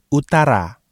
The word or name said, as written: utara